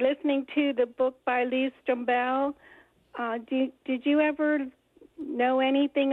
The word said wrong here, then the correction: Strobel: I've always heard his name pronounced Strobel, but then again, I don't know anybody else to talk to about it.